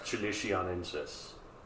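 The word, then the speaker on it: qilixiaensis: And yeah, again, I had to go, you know, scouring the internet, like, how the heck do you pronounce this? It is, in fact, chilicianensis.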